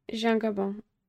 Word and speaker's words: Jean Gabin: How do you say his name? Jean Gabin? – Jean Gabin, yeah.